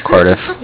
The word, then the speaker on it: Cardiff: Yeah, with Cardiff. – With Curtis? – With Cardiff. – Can you spell that for me, please? – Yeah, I know it sounds weird. It's C-A-R-D-I-F-F, like Cardiff, Wales.